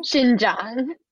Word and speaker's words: Xinjiang: You're going to have to help me pronounce this. Beijing? – Xinjiang. – Xinjiang. Oh my gosh, I butchered it.